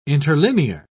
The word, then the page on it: interlinear: British English